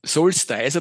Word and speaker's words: Solstice: Solstice, aber bevor wir zu Solstice kommen, ja.